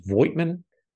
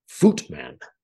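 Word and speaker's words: Voigtmann: I believe pronounced it Voigtman. No. The V is sharp like an F, and the I is silent. Something like Footman.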